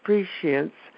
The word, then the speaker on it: prescience: There's an SH. It's so weird. Because I couldn't pronounce it either. It's prescience or prescience, whichever you choose.